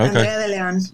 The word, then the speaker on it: Andrea De Leon: I know it's a Mexican name, so yeah. – Okay. – Andrea De Leon, but I don't expect anyone to roll their R's.